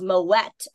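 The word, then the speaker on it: Moët: By the way, it is Moet and Chandon, which by the way, everyone thinks it's Moet because it's supposed to be super French. The guy's last name is Moet. So it is you do pronounce the T. It is Moet and Chandon.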